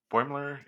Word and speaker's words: Boimler: Boimler. Boimler is the pronunciation. Yeah, Boimler is Boimler.